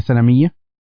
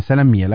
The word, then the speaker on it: سلمية: سلمية، كذا تقولوها سلمية، سلمية، لأنه شايف ضبطها في بعض الكتب القديمة سلمية، لكن يبدو سلمية تنطق اليوم.